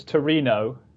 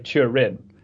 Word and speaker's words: Turin: Well, in Italian, it's Torino. So yeah, you're pretty close. But in the English UK pronunciation, we say Turin. But okay. – Italian, you're closer to the Italian pronunciation, I think.